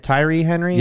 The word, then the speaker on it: Tyree: Tyree Henry? – Yeah.